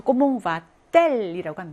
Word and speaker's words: va-t-elle: 그러면 모음하고 모음이 이제 만나게 됩니다. 도치를 하면서. 그럼 프랑스어는 이제 그 발음의 특성상 문장을 구성을 할때 모음과 모음이 만나는 거를 좋아하지 않아요. 그래서 이 발음을 띄어요. 이렇게 띄어준다. 구분한다라고 이해하시면 돼요. 그래서 이렇게 하나를 집어넣어요. 때라는 철자 하나 집어넣습니다. 그래서 이렇게 바꿔요. 그래서 comment va-t-elle이라고 합니다.